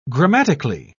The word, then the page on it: grammatically: British English